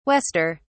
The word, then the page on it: wester: British English